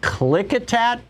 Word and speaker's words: Klickitat: Betsy in Klickitat, Washington. Am I saying that right, Betsy? – Yes, you are saying that right, and it's on the Klickitat River, which joins the Columbia about you know an hour and forty minutes east.